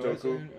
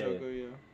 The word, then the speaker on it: Njoku: Njoku. – Yeah. Njoku, yeah.